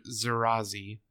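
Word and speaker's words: Zeraze: Because the Hatut Zarari, or Zarazi, sorry, I said that wrong.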